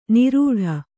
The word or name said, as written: ನಿರೂಟ